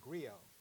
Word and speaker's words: griot: This person, the French gave him the name griot, and it's spelled G-R-I-O-T, but you don't pronounce the T like you don't pronounce it for Brigitte Bardot.